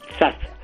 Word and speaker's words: Zaz: Zaz. – Bueno, pues a mí me resulta muy difícil. – A ver, hago una zeta, no la categórica castellana, ni siquiera la S líquida.